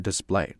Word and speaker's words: display: 然后第二次出现的是说 on a larger display，这也是做名词啊，表示显示屏、显示器啊。但这两个词呢，它的读音都是重音在第二个音节啊，它不存在说第一个音节的问题。